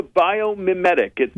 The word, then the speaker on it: biomimetic: We are speaking with Director of Biomimetric and Cognitive Robotics Lab. Did I pronounce everything correctly? – It's actually the biomimetic. – Mimetic. – Biomimetic. Okay.